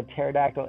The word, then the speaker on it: pterodactyl: Why can't you hear a pterodactyl in the bathroom? Because it has a silent P.